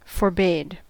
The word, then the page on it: forbade: American English